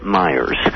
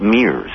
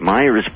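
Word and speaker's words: Miers: and he mispronounced her name. He called her Harriet Mears. I hope, by the way, that I'm pronouncing it correctly and that it's Anderson and not myself who's inaccurate here.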